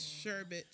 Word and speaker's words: sherbet: It's sherbet. – My everything hurt, yo. – Ooh. I didn't know that there was no R. – It's no other R.